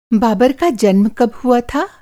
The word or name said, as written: बाबर